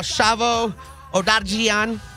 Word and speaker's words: Shavo Odadjian: I'm talking about Shavo Odadjian.